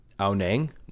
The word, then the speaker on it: Ao Nang: So we had booked for 3 nights in Ang Nong. And I'm probably saying that wrong, but whatever. Which is the main town in Krabi. Ao Nang. Sorry, I've been saying it wrong this whole time.